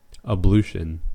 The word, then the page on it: ablution: American English